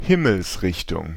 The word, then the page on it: point cardinal: Paris; /pwɛ̃.kaʁ.di.nal/